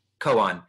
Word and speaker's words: koan: And I think Zen Koan, is that how you pronounce it? Koan, not Koan. – Koan.